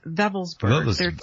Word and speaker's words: Wewelsburg: Yeah, they pronounce the letter W V. – Ah, Wurzburg. – Ve- Wewelsburg.